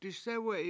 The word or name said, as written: disEwe7i